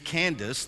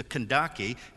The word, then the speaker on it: Kandake: It reads Candace. The proper pronunciation is the Kandake, and that's not a name.